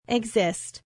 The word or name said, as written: exist